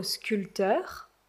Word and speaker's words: sculpteur: Un autre exemple, vous connaissez sûrement le célèbre sculpteur et peintre italien Michel-Ange. So do you remember? Sculpter is to sculpt, but in French we don't pronounce the P.